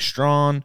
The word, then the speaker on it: Strachan: So Quiddy Pay, defensive end Dio Odangbro, safety Sean Davis, wide receiver Mike Strawn. I said that right, didn't I? – Yeah, you did. Good job. – It's Strachan is how it's spelled.